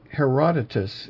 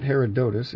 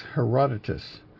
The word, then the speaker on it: Herodotus: It reminds me, Herodotus is the correct pronunciation of the famous historian, the father of history. It's not Herodotus, it's Herodotus.